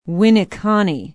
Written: winneconne

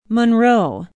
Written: monroe

